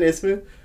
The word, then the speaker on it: Isman: Is it Isman Isman? – Isman.